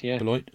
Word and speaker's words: Beloit: Currently lives in, tell me if I'm saying this right, Beloit, Wisconsin. – Beloit.